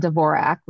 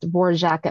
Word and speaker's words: Dvorak: It is not pronounced, you know, maybe she wanted it pronounced Dvorak at one time, but very quickly Dvorak became the common pronunciation. So she was always Anne Dvorak. So somebody found some obscure quote that they put on Wikipedia 20 years ago. And so everybody always has to say it's pronounced Dvorak.